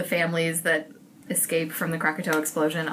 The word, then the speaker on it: Krakatoa: The next book on my list is, excuse me if I pronounce this wrong, Krakatoa. – Yeah, that's excellent. Krakatoa, The Day the World Exploded by Simon Winchester.